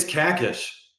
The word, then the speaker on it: Kakish: It's Kakish. But Kakish, that's okay.